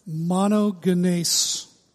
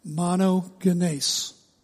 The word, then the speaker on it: monogenes: Monogenes. Say it again. Monogenes. You can read it monogenes if you want. Skinny jeans, monogenes. But the Greek pronunciation is monogenes, and it means one of a kind, or one and only.